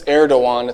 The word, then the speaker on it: Erdogan: Don't pronounce the G like President Trump did at one point. It's Erdogan. It's a silent G.